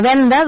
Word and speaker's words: venda: I ja que he parlat de venda, doncs venda en català eh, s'escriu i, i la diem aquesta paraula amb una D, no amb una, una T. Venda.